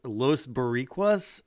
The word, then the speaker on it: Los Boricuas: So the next match is DOA versus Los Boricuas. Am I saying that right? I think that's right. – That's correct, yep.